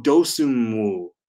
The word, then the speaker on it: Dosunmu: I finally learned how to say his name. Ayo Dosunmu. Dosunmu.